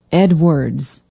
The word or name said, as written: edwards